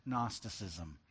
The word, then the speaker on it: Gnosticism: In particular, we said, a philosophy known as Gnosticism. That word is spelled with a G and an N.